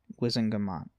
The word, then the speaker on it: Wizengamot: possibly as subject to the approval of Wizamagot. There. Gosh. It's a High Court of Law of Parliament. Wizengamot. That's how you say it.